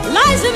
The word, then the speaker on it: Liza: It's Liza with Z, not Lisa with an S, because Lisa with an S goes snobs. It's Z instead of S, lie instead of Lee. It's simple as can be.